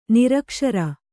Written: ನಿರಕ್ಷರಿ

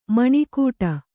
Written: ಮನಗೂಡು

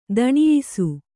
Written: ಧನ್ಯಾಸಿ

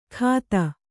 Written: ಖಾತ

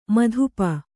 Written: ಮದಿಪು

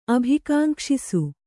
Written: ಅಭಿಕಾಂಕ್ಷಿಸು

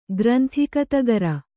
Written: ಗ್ರಂಥಿಕತಗರ

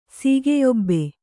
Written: ಸೀಗೆಯೊಬ್ಬೆ

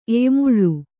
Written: ಎಯ್ಯಮುಳ್ಳು

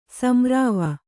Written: ಸಂರಾವ

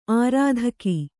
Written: ಆರ್ಥಿಕ

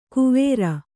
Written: ಕುವೇರ

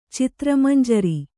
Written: ಚಿತ್ರಮಂಜರಿ

